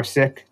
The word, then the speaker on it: Sikh: So I'm Sikh or Sikh, depending on, you know, if you don't want the Americanized pronunciation of it.